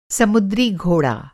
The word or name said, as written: समुद्री घोड़ा